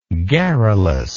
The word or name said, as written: garrulousness